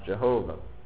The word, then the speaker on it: Jehovah: which we believe is pronounced Yahweh, which 2 or 3 generations ago was generally pronounced Jehovah.